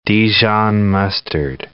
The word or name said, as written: Dijon mustard